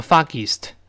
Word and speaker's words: foggiest: 另外呢，不要读 foggiest，这是读错了啊。我们先读 foggy，然后后面有个 est。所以呢，它都是发音的。我们大声读一遍，foggiest。先读 foggy，然后后面还有个 east，east，所以 foggiest，foggiest 是这么一个声音啊，而不能读成 foggiest 啊。